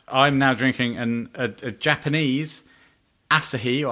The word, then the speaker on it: Asahi: It was a long time ago, but I think it's pronounced Asahi. – Yes. Well done.